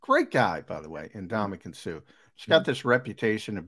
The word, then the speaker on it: Ndamukong Suh: Help me out with Sue's first name. I'm pronouncing Ndamukong Suh.